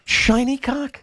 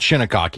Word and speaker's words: Shinnecock: Shiny Cock? – Well, I mean, we call it Shinnecock here, so, I mean, different pronunciations for different people.